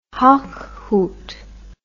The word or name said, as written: Hochhuth